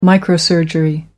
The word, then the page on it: microsurgery: American English